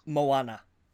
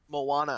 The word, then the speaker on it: Moana: Is it Mona? – Moana. – Moana. I knew I was spelling it wrong or saying it wrong.